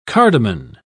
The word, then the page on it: cardamon: British English